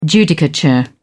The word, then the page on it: judicature: British English